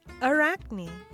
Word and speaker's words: Arachne: Arachne.